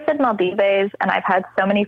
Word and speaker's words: Maldives: No, and you got three people in this room all separately looking up how to pronounce Maldives. – Maldives.